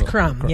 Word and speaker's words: Krum: It's Krum, yes.